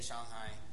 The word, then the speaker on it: Shanghai: But the A in Chinese, it's like you pronounce like ah. So Wuhan, it's like W-U-H-A-N, it's Wuhan. Shanghai, S-H-A. So that's just how they do it. They say Shanghai.